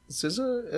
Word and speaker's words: SZA: SZA? What is that? SZA? S-Z-A? That's how you pronounce her name? – Uh-huh.